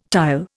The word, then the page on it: tile: Indian